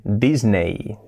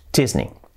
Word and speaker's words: Disney: Pues nosotros decimos Disney porque se escribe Disney, pero que he dicho antes, que las palabras acabadas en E-Y, pues es una I. Por lo tanto, sería Disney, Disney, Disney.